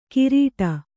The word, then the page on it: ಕಿರೀಟ: kirīṭa; Kannada